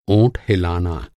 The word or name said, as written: ओंठ